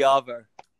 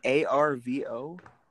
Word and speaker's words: arvo: Do you spell it A R V O? – Yeah. – Yeah, A R V O. – Once again, you don't pronounce the R.